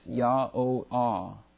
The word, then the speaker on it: YHWH: And then the last H, again, Hey, H-E-Y, is again pronounced Ah. So if you look at the phonetic way to pronounce YHWH, it's Yah-O-Ah. We would say Yahweh in our English, but they in the Hebrew would say Yah-O-Ah.